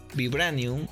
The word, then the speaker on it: Vibranium: Vibranium.